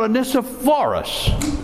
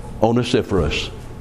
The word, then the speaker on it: Onesiphorus: And then he talks about Onesiphorus, Onesiphorus, I'll say that right in a second here. He stayed with him through the toughest times, verse 16 says. And thus he was worthy of every commendation that Paul could give. A true brother, a loyal friend, Onesiphorus. I should never have mispronounced his name.